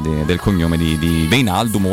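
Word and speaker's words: Wijnaldum: Come dice l'amico Alessandro Colantoni, si dice Gini Veinaldum.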